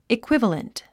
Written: equivalent

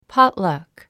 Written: potluck

